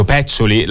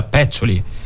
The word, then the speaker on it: Peccioli: Mi ha detto: Peccioli, la E è chiusa, mica siamo a Milano, ma si pronuncia Peccioli.